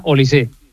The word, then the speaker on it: Olise: Lleva acento, pero se pronuncia Olise.